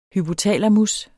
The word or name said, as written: hypotalamus